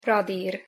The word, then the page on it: radir: Hungarian